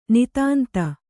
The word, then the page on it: ನಿತಾಂತ: kannada